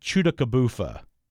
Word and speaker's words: Tchoutacabouffa: T-C-H-O-U-T-A-C-A-B-O-U-F-F-A. It looks like Chutakabufa. – Oh my God. – Wow. You got it.